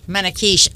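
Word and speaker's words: manakeesh: So, correct me if I'm mispronouncing this. Manakish? – Manakish. Okay. – Oh, Manakish, yeah. – Manakish, yeah. That's...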